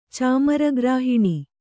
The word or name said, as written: ಚಾಮರಗ್ರಾಹಿಣಿ